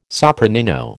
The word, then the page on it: sopranino: American English